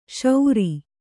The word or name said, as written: ಶೌರಿ